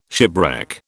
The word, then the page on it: shipwreck: British English